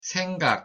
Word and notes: 생각: Korean

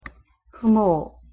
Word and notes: 부모: Korean

